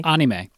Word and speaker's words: anime: Anime? – Anime.